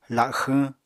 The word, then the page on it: laxwñ: apatani